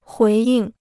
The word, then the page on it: 回应: huí yìng